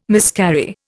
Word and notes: miscarry: Indian

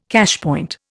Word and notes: cashpoint: Indian

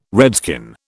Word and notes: redskin: British English

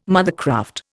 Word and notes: mothercraft: Indian

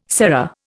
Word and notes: serra: Indian

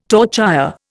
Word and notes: torchère: Indian

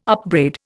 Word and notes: upbraid: Indian

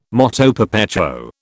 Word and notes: moto perpetuo: British English

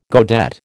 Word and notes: godet: American English

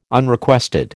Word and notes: Unrequested: American English

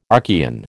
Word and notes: Archean: American English

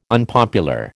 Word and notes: unpopular: American English

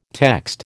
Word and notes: text: American English

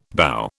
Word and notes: bough: British English

